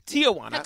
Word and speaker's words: Tijuana: Tijuana.